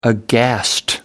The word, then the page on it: aghast: American English